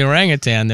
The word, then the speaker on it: orangutan: You hear about the orangutan that went to... Never mind. – And finally, something that happened for the first time in 2024. – You pronounced orangutan correctly.